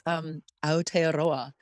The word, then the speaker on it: Aotearoa: No, actually, you kind of nailed it. It's Aotearoa. And okay. That is the... That is the native pronunciation of New Zealand, which, as you can imagine, was not the original name of this island.